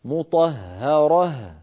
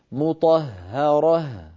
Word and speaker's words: مطهرة: مطهرة، رقق الهاءات. – مطهرة، الهاء الأخيرة عم تطلع معك مفخمة شوي. أعد فمك عند نطقها إلى الوضعه الطبيعي. مطهرة. – مطهرة. – أيوة هذا المطلوب، هذا المطلوب تماما.